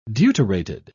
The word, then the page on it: deuterated: British English